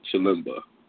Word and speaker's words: Chilemba: We've seen Chilimba, how do you say his name? Where the fuck is it? Chilimba. Yeah, Chilimba.